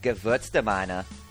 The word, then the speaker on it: Gewürztraminer: Is it the Gerstraminer? – Yeah. That's pretty good. – I don't think that's right. But I think the W should be pronounced as a V, so like Gewürztraminer.